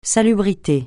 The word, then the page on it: salubrité: francais